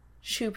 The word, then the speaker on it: choux: Can I say that I've heard choux pastry a long time and I've always pictured the word spelled like shoes on your feet.